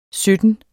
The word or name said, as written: SYTTEN